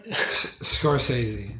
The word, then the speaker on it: Scorsese: Scorsese.